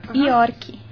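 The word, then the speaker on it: Iorc: Tiago, T-H-I-A-G-O. ¿Así se escribe? – T-I-A-G-O. – G-O. – Tiago. York. – I-O-R-C. C. – C. – Ok, York.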